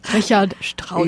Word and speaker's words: Richard Strauss: Richard Strauss.